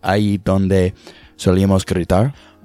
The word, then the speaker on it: Ahí Donde Solíamos Gritar: And I'm going to give my best shot at pronouncing this, but it's Ahí Donde Solíamos Gritar.